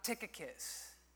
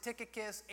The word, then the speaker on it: Tychicus: Tychicus. That's just kind of a fun name to say. I found really smart people that pronounce it Tychicus and Tychicus.